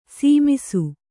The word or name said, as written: ಸಮಸು